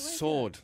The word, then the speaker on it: sword: That were is silent. So it's sword.